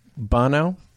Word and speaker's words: Bono: The lead singer of U2, I believe his name is Bono.